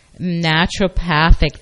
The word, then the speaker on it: naturopathic: neuropathology. How do you pronounce that? Naturopathic, thank you.